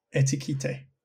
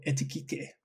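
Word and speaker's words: Ekitike: Etiquette. But you've got to say it in a very French way. Hugo Etiquette.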